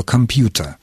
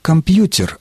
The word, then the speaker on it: компьютер: Поэтому, когда в наш язык вошло слово, английское слово «компьютер», мы стали произносить его не как «компьютер», а как «компьютер».